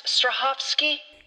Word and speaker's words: Strahovski: Yvonne Strahovski. I think that's right. It's a hard name. – I'm sure you didn't. – I know. – It's pronounced Strahovski.